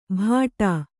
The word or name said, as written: ಭಾಟಾ